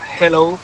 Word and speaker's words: hello: Y la segunda, dile a Elena que su dicción en castellano es la más perfecta que conozco, pero que cuando diga hello, que no pronuncie la J, que no es hello, que es yeah. Hello, que la aspire la J y no pronuncie la J potente castellana.